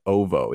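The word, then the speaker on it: Oovo: So this ship, quote, the Slave One, was actually a attack ship and a defense ship surrounding the planet of Uvu 4. I think that's how you say it. Ovo. It's O-O-V-O-O.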